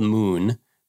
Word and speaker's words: MXXN: It's called Moon, but it's spelled MXXN.